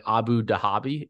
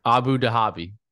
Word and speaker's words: Abu Dhabi: You were almost there. Give it another try. Just say it faster. – Abu Dhabi. – Okay, let's not emphasize the H.